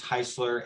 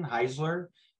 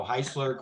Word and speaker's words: Heisler: So my full name is Matthew Heisler, and it's Heisler. And a lot of people go with the Z sound for some reason, Heisler, but it's originally an Austrian name. And the simplest way I've found to explain it is it rhymes with Chrysler. So Heisler, Chrysler.